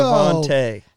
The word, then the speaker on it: Devontae: Devontae. So the A, it's the long A at the end.